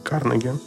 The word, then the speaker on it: Карнеги: Вот э, Дейло Карнеги, по-моему, правильно ударение Карнеги,